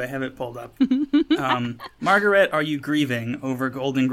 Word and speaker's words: Margaret: Can we call it Margaret then for the rest of the podcast? – Okay, let's work on it.